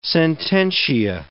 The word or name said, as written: sententia